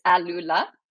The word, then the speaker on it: AlUla: Well, it's ALULA.